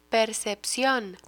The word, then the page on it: percepcion: Spanish